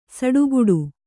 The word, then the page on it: ಸಿಟ್ಟುಗುಟ್ಟು: Kannada